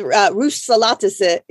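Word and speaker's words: Rus salatası: Well, how do you say it again? Can you say the Rus? It's called Russolatası in Turkish. – Russolatası.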